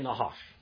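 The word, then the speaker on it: Nahash (נחש): Say Nahash. – Nahash. – If you got to clear your throat. Say that. Say it right. – Nahash. – Nahash. – Nahash. – Like clearing your throat. Nahash.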